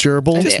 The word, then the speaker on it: herbal: I don't think they pronounce the H in herbal.